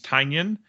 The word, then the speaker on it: Tynion: The first is there is a new comic by James Tynion, and due to last podcast on the left, I now know that's how it's pronounced.